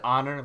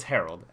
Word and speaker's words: Harold: Yeah, dude, he said Honor Landry. Like, what? I can only imagine. It's Harold.